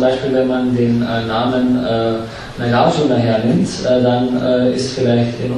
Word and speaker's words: Nagarjuna: Nagarjuna, that's the actual Sanskrit pronunciation. It ends in two short syllables, Nagarjuna. Mm-hmm. Now, I hear somebody say Nagarjuna, and that sounds terrible to me. But I can understand that they're saying Nagarjuna. But in my mind, the sound of that name is represented by Nagarjuna. The accent is on the second syllable, not at the third syllable.